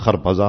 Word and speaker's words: خربزہ: اور اس کے بعد خرپزے آئے جس کو ہم اردو میں کہتے ہیں خربوزہ, ہے نا، فارسی میں اس کا تلفظ خربزہ اور ترپزہ، وہ آئے تو چھلکے کیا کیے انہوں نے، کھانے لگے خربوزہ اور چھلکے مجھے مارنے لگے.